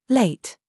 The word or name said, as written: LIE